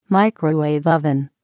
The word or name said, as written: microwave oven